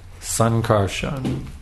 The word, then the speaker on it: Sankarshan: Just like if I were to tell you to carefully pronounce my name, Sankarshan, Das, Adhikari. And just when Sankarshan, not carefully, you see, just mumbling something out. That's not carefully chanting my name.